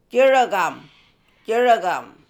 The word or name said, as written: Jiregam